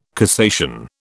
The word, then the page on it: cassation: British English